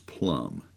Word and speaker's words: plumb: is make sure that it is plumb, P-L-U-M-B. It's almost a silent B, plumb, vertical, straight up.